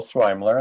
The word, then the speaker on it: Sweimler: My name is Joel Swimler, and that's how you pronounce my last name. It's a toughie.